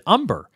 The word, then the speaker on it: Umbehr: What's interesting, by the way, about Josh Umber, and his last name is spelled U-M-B-E-H-R, is that when we were chit-chatting before our conversation today, I said to him, you know, that's kind of an unusual last name. I've known one other person with that last name, and he just pronounced it Umber. And he said, oh, yeah, I pronounce it that way as well.